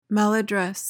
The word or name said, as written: maladdress